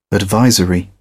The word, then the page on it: advisory: British English